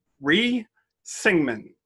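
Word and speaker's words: Rhee Syngman: So South Korea elected, I'm going to try not to butcher this. I have listened to pronunciations, but Ri Syngman as their first president of the Republic of Korea. He is a controversial... – Good job.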